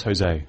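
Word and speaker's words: José: How do you say that? Is that Josie or Jose? – Yeah, that's Jose.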